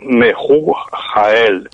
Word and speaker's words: Mehujael: Sí, todas son repeticiones, pero Mehujael. Hay que pronunciarlo en dos partes. Mehu Porque la Ju, la H es J también, ¿sabes? – Pero bueno, vamos a hacer la aspirada.